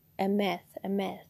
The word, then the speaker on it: Emeth: Okay, so now let us get into our next one, which is Jehovah El Emet. Emeth, yes. So, Jehovah El Emeth. Emeth is spelled E-M-E-T-H, and that means Lord God of Truth.